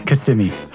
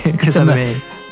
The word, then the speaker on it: Kissimmee: they have a control center in Kissimmee, and there are a couple different people responding." – That's how you can tell the tourists. They call it Kissimmee, but it's really... – Whatever, man! – It's Kissimmee.